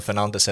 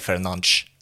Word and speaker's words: Fernandes: Heter han Bruno Fernandes eller Fernandes till exempel? Ja, just det. Och då är faktiskt den första rätt.